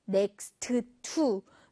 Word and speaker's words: next to: 발음하실 때 next to, next to 하지 마시고요. to 사운드는 한 번만 내주세요.